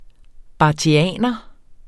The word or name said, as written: barthianer